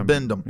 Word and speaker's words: Bibendum: Bibendum? – Yeah. – Bibendum.